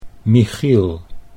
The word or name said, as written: Michiel